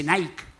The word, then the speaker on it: Nike: Y me dice, no se dice Nike, se dice Nike. Pero en la caja dice Nike. Sí, pero la I se pronuncia ay.